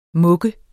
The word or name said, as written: muk